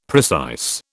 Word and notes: precise: British English

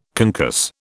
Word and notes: concuss: British English

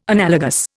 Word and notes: analogous: Indian

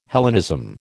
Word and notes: Hellenism: American English

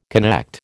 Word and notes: connect: American English

